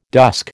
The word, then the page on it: dusk: American English